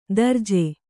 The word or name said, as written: ದರ್ಜೆ